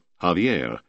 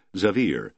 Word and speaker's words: Xavier: Older settlers who spoke Spanish wanted to call it Javier, with accent on the last syllable, but newcomers promptly changed that to Xaver, with accent on the second. Within a few months, it was agreed that the county was Xavier, with a heavy accent on the second syllable, and so it became.